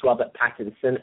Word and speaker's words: Robert Pattinson: To pronounce his name properly is Robert Pattinson, and I have never seen the Twilight Zone movies.